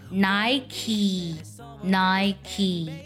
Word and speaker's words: Nike: 但是它的英文呢，它那个 K E 它不读作科，它读作 Nike Nike。